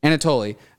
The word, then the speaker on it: Anatoly: Anatoly.